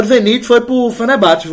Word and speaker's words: Fenerbahçe: Foi pro Fenerbahçe. É assim que fala. Eu conheci uma turca lá na França. Que eu perguntei pra ela como se pronunciava. Fenerbahçe.